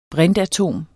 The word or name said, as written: brintatom